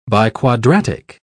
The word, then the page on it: biquadratic: British English